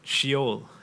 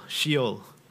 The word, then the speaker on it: Sheol: So the first word, in fact, in the word cloud, was Sheol. And that's pronounced she-ol, Sheol.